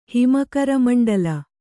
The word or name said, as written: ಹಿಮಕರಮಂಡಲ